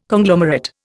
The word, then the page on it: Conglomerate: Indian